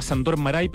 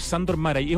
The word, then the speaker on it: Márai: Así se pronuncia, porque tiene acento. Yo pensé que era Sándor Márai, pero es Sándor Márai.